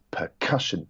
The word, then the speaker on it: percussion: So, for example, the word percussion, it's percussion. That middle syllable is emphasized rather than percussion or percussion.